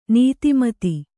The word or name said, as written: ನೀತಿಮತ್ತೆ